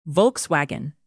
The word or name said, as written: volkswagen